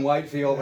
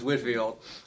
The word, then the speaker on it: Whitefield: I want to call him Whitefield, but it's Whitfield.